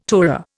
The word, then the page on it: tourer: Indian